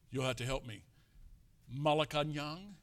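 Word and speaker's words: Malacañang: Malacanang. – Thank you. I got to go to Malacanang.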